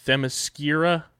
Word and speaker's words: Themyscira: The HBO Max show will take place on the island of, I'm totally going to butcher this, Themyscira, the birthplace of Wonder Woman.